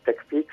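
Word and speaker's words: TechPeaks: Quindi sì, TechPix si scrive intanto Tech con la H alla fine e Pix come le montagne, quindi P-E-A-K-S, sì.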